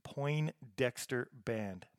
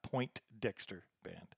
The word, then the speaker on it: Poindexter: It's Poindexter Band, not Poindexter Band. So, yeah, you won't find very much if you look for it the other way, but it's Poindexter.